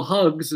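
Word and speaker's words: Hux: So that was either a clone, or we just like episode one, we just like have, you know, Brendel Hugs is how you properly pronounce his name, Commander Hugs.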